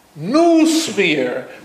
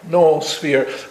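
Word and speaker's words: noösphere: To explain the umlaut, the two dots on the top of the second O, is because if you didn't put it on, a lot of English-speaking persons would pronounce it noosphere. But it's not noosphere, it's noosphere, you see?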